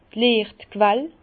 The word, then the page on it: d' Liechtquell: Bas-Rhin; Herrlisheim